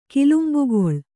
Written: ಕಿಲುಂಬುಗೊಳ್

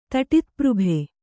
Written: ತಟಿತ್ಪøಭೆ

